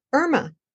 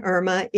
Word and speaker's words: Irma: When people see my name in writing, I-R-M-A, they often say Irma. And that is one pronunciation of this name. But my name is Irma, which is the pronunciation not just in Spanish. It's French, Italian, Russian, Danish. Many countries, the name is pronounced Irma. So often when I introduce myself, I have to explain to people that I'm not Irma, it's Irma.